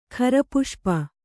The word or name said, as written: ಖರಪುಷ್ಪ